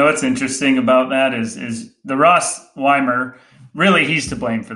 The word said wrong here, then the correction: Wiemer: Wiemer. – Wiemer, sorry. – Rhymes with Wiener. How could you ever? – Yeah.